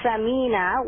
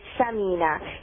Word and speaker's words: Samina: But sometimes if you don't pronounce it correctly, because we generally don't have, some may not be able to pronounce the sa, and we say Samina. If you look at the word Samina, it means fat.